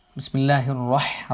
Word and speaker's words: بسم الله الرحمن: ബിസ്മില്ലാഹിർ റഹ്മാൻ എന്ന് പറയുമ്പോൾ തഫ്ഹീമോടുകൂടെ സ്വരഭാരത്തോടുകൂടെ വായ നിറച്ചുകൊണ്ട് ഉച്ചരിക്കേണ്ട അക്ഷരമാണ് റ. ഹ എന്ന് പറയുമ്പോൾ അതിൻ്റെ ഭംഗി ഉണ്ടാവണമെങ്കിൽ ഹൽക്കിൽ നിന്ന് ശരിയായ സ്ഥലത്ത് നിന്ന് ഉച്ചരിക്കണം. ബിസ്മില്ലാഹിർ റഹ്മാനിർ റഹീം.